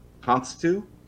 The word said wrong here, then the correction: Cousteau: It's pronounced Cousteau, but yes.